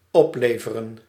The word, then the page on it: rapporter: [ʁa.pɔʁ.te]